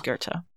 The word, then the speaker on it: Goethe: It was Goethe. – I never would have guessed it. – G-O-E-T-H-E. Thankfully, I've heard somebody pronounce that out loud because it doesn't sound like it looks like.